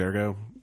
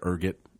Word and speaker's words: Ergot: If you like stuff like Kramer's Ergo or Kramer's Ergot, I don't know how to say it. – I'd say Ergo.